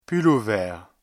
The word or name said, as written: pull-over